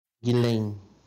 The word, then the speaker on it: Ghislaine: Ghislaine.